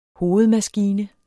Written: hovedmaskine

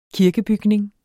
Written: Kirkebygning

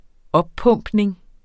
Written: oppumpning

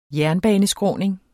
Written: jernbaneskråning

